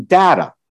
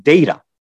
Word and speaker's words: Data: And while Data and Dr. Pulaski are studying the child and looking at their readings, she calls him Data. And then I love it. He just turns to her and says, Data. And she goes, excuse me. And he goes, it is pronounced Data.